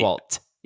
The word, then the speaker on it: Oswalt: It's Oswalt. Yeah, that's right. No one says that, though. Everyone calls him Patton Oswalt, like he's Oswald Cobblepot. Yeah, no one actually probably usually pronounces the T.